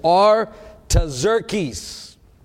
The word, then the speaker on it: Artaxerxes: In late autumn, in the month of Kislev, in the 20th year of King Artaxerxes, and I pronounced that right, I looked it up on the pronunciation, it's Artaxerxes. Reign, I was at the fortress of Susa.